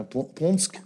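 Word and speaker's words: Płońsk: Et il est né dans c'est pas un petit village, hein, Ponsk, c'est comme ça que ça se prononce, c'est une cinquantaine de kilomètres à l'ouest de Varsovie.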